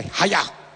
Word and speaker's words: haya: Now, if you've ever done any kind of ninjutsu, or karate, or judo, who can say haya? That is literally how it's pronounced. It sounds a little bit Japanese.